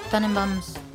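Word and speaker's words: Tenenbaums: La de los Tannenbaums.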